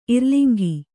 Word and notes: ಇರ್ಲಿಂಗಿ: irliŋgi

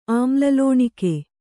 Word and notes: ಅಮ್ಲಲೋಣಿಕೆ: amlalōṇike

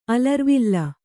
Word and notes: ಅಲರುವಿಲ್: kannada

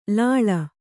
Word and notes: ಲಾಲಿ: Kannada

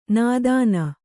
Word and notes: ನೋದನ: nōdana

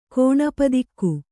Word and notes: ಕೋಣಪದಿಕ್ಕು: Kannada

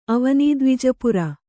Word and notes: ಅವನೀದ್ವಿಜಪುರ: Kannada

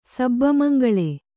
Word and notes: ಸಬ್ಬಮಂಗಳೆ: Kannada

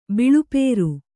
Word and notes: ಬಿಳುಪೇರು: biḷupēru

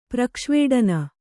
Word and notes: ಪ್ರಕ್ಷ್ವೇಡನ: Kannada

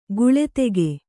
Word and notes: ಗಳತಿಗ: gaḷatiga

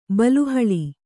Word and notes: ಬಲೆಹುಲ್ಲು: bale hullu

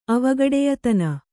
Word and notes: ಅವಗಡೆಯತನ: avagaḍeyatana

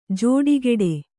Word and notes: ಜೋಡುಗೂಡು: jōḍugūḍu

